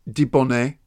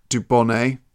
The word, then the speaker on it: Dubonnet: In French, it's Dubonnet. In English, it's Dubonnet.